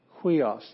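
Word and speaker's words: huios (υἱός): Huios. That's why we pronounce it huios.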